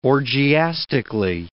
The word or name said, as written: orgiastically